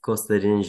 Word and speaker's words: Kostadin: My name is Kostadin Zhelev.